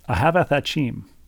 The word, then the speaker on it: Ahavath Achim: I was going to say, I'm not going to pronounce this correctly, Rebecca, and Dr. Siegel provides a pronunciation key. – Okay, let's hear it. – Phonetically, it is Ahavath Achim. Ahavath Achim.